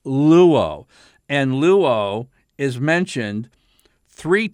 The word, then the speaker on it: luo: But, you know, your first verb that you learn in Greek 101, New Testament Greek, you start with the verb luo. In English, this would be L-U, long O.